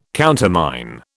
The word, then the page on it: countermine: British English